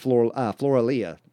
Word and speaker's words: Floralia: Roman Catholic traditions of crowning statues of Mary with flowers on May 1st have Roman pagan roots from that Floralia, that's how it's pronounced, Floralia tradition before Christianity and Roman Catholic traditions were formed.